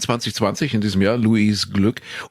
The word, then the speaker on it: Glück: Und sie heißt wirklich so, sie wird so geschrieben, Glück, und eigentlich ja auch so ausgesprochen, weil sie österreichisch-ungarische Wurzeln hat, Louise Glück. Aber die Amis können nicht Glück sagen. Sie sagen dann Louise Glick.